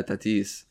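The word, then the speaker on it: Tatís: There is an accent on the I. You're there right. Yeah, Tatis.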